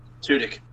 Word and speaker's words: Tudyk: I never pronounce his name right. Alan Tudyk? Tudyk. – Tudyk.